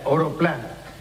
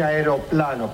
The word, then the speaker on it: aeroplano: Yo diría oroplano. ¿Por qué está, no? Arioplano. – Por favor, ¿cómo se dice? – Debe decirse aeroplano, profesor.